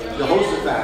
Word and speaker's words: Jehoshaphat: Jehoshaphat.